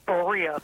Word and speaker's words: Berea: Joe is in, I'm going to say Berea, Ohio. Did I pronounce that correctly? – It's Berea.